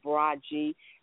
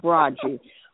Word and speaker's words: Braggy: The way you really pronounce it, the way you really pronounce it is Braggy. I don't say too much because a lot of people can't pronounce my name. Sometimes I have a hard time pronouncing my name, but it's pronounced Braggy.